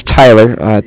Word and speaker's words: Tyler: My name is Tyler, T-Y-L-E-R, and I'm calling with Cardiff. It's spelled C-A-R. D-I-F-F, as in Frank Frank.